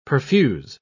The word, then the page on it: perfuse: British English